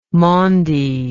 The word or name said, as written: maundy